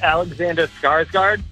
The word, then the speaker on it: Alexander Skarsgård: So I'm going to go with Alexander Skarsgård.